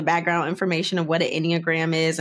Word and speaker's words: Enneagram: So Today we're going to talk about the Enneagram. Is that how you pronounce it? – That's how you pronounce it.